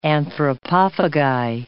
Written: anthropophagi

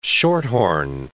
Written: shorthorn

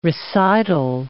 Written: recital

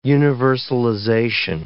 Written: universalization

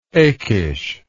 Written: Achish

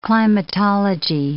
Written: climatology

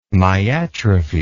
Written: myatrophy

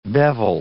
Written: bevel

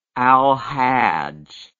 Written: alhajj

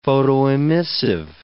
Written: photoemissive

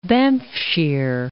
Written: Banffshire

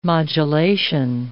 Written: modulation

